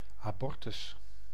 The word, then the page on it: avortement: France; [a.voʁ.tə.mɑ̃]